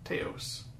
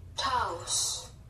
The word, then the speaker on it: Taos: Taos. Let's see. – Yep. – Taos.